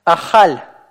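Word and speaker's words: Ахаль (אכל): Ахаль, ахаль. Обратите внимание, что каф у нас здесь произносится по щелевому варианту. Х. Вот, вот это вот как раз харкающий звук, который вам нужно научиться произносить.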